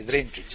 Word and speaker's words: Drinčić: Con victoria del Partizan, que le ha ganado 1-0 a la Estrella Roja con gol de Drinčić. ¿Lo he dicho bien? – Drinčić, sí.